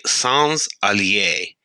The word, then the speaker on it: Sans Allies: To be perfectly correct, or at least to be as close as I can get it with my terrible French pronunciation, it would be Sans Alise. That second word is about 3 syllables, but they're said in close succession together.